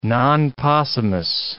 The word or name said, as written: non possumus